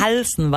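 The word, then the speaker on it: Halzen: Der Neutrino-Forscher Francis Helsen. Habe ich das so richtig gesagt? – Ich glaube Halsen, weil er halb Belgier ist, wird es Halsen gesprochen.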